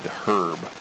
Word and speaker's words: herb: It's the H is silent, but when it's a man's name, it's the H is sounded, herb. So it's not herb, he's not being a cannibal.